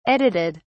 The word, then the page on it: edited: British English